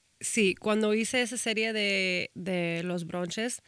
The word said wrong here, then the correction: brunch: ¿Cómo se dice? Brunch. – Brunch, es un brunch.